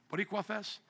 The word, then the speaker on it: Boricua: How do you say it? Boricua Fest? Thank you, my Boricans.